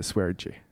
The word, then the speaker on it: Sverige: När, när, när du vet, det finns en kille som heter, eh, en, en youtuber som heter Swergy Ja. Han, det, skrivs som Sverige, men han uttalar det så av någon ja.